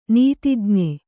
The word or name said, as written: ನೀತಿಜ್ಞೆ